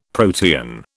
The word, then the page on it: protean: British English